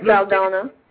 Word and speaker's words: Saldana: Zoe Saldana.